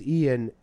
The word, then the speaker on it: Ian: No, fucking, like, the reason my name is Ian, and if you guys don't know how to fucking spell it, it's three letters.